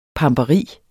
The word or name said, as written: pamperi